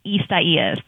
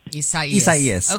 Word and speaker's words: Isaias: But you guys pronounced it perfectly. Isaias. – Isaias.